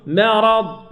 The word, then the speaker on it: مرض: قال وباء، قال من مرض، نعم من مرض، براء، ما، لا مرض، الراء مفخمة والميم مرققة، نقول مرض،